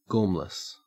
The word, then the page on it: gormless: Australian English